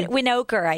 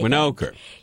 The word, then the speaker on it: Winokur: Winokur. – Winokur.